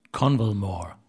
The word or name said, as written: Convalmore